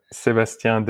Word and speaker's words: Sebastien: All right, say your name properly. This is a dumpster fire. Go ahead. – Sebastian Dehisten. – Oh, sounds so much better.